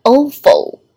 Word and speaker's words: oval: 那么这个单词我们注意一下发音， oval 要纯音，结尾是一个 L 的舌色音， oval face。